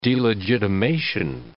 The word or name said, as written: delegitimation